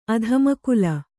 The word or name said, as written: ಅಧಮಕುಲ